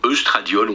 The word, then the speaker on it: œstradiol: Mais avant l'ovulation, il y a une montée importante d'une hormone sexuelle qu'on appelle l'estradiol, ou œstradiol, on peut prononcer comme on veut.